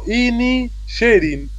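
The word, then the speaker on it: Inisherin: Banshees of Inishaman. – Y no, Inishirin, Inishirin, sí.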